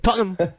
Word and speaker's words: Tottenham: So I believe you pronounced it Tottenham. It's we say Tottenham. – Tottenham. – And Tottenham. – That was the stadium that they played in. So that's my team, Tottenham Hotspur. And the podcast I do is all about the football team, soccer team for you, Tottenham Hotspur. – Tottenham. Am I saying it right there? – Yeah. – Okay. – Tottenham, that's it. That's it. With like a thick kind of North London Tottenham.